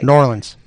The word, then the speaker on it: New Orleans: I think you are finally pronouncing New Orleans correctly. – New Orleans. – Very good.